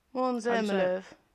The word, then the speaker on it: Måns Zelmerlöw: Måns Zelmerlöw. Måns Zelmerlöw.